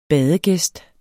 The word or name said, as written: badegæst